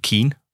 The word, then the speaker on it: Keen: Og øh, jeg ved ikke, hvordan man udtaler dit navn, kære lytter. Undskyld. Keen? Keen? Det er Keen. Keen? K-E-E-N?